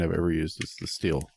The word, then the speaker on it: Stihl: According to them, it is pronounced steel.